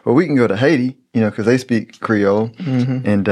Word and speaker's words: Haiti: which is the proper way to say Haiti.